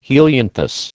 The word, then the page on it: helianthus: American English